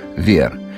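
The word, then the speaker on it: verre: Но дело в том, что на французском языке слово «стекло» – «вер» произносится так же, как название меха особой выделки – «вер».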